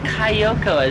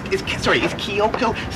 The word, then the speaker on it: Kyoko: Because you said Kayoko as if she's a coyote or something.